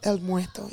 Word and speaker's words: El Muerto: And the film is called El Muerto. Did I say it right? – El Muerto, yes.